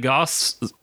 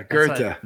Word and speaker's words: Goethe: I read Goss, the Sorrows of Young Werther. Goethe? – Yeah, Goethe. – That's spelled Goth.